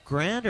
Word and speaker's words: Grande: I think it's Grande. I don't know if it's Grand or Grande. There's any, huh? Okay, it's Grand, but it's spelled Grande.